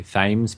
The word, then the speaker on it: Thames: I'd also like to add that it's really difficult for me to say Thames because this is spelt the same way as the River Thames.